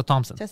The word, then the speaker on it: Thompson: Tessa Thompson. – Yeah. The H is silent.